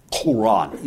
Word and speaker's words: Quran: Quran. You don't ask how you're supposed to pronounce that Q. It's not supposed to sound like a K. It's got to be done further back in your throat.